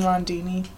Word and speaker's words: Rondini: Megan Rondini. – Could you spell that last name for me? – R-O-N-D-I-N-I.